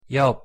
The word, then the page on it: 옆: Korean